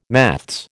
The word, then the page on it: maths: American English